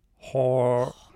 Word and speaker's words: horror: Horror. Sorry, it's two syllables.